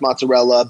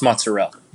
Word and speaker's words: mozzarella: If I'm going wood-fired and you want to do the fresh mozzarella, basil, maybe a little tomato chunk doesn't hurt. – It's pronounced mozzarella. – Mozzarella. – There you go.